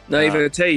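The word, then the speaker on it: naïveté: A hasty backjacket description of the American dream, an unknowable eldritch alien apex predator's idea of fair competition, extreme naivety... I don't think I'm saying that right. – Naivete, you fool.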